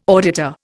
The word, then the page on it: auditor: Indian